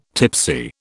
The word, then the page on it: tipsy: British English